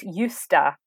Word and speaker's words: Justad: So you don't pronounce the J or the D. So it's Justa.